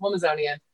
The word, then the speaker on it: Womazonian: I'm like, "It's Amazon. Like Amazon woman, Womazonian." So it's Womazonian.